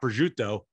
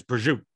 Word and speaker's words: prosciutto: The Italian culture, they drop a syllable. So it's not prosciutto, it's prosciutto. It's not mozzarella, it's mozzarella, right?